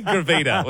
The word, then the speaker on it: gravida: I have actually pronounced that one wrong now because you've rattled me. Gravida was what I was trying to say, which is the medical term for how many times a woman has been pregnant.